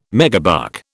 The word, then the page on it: megabuck: British English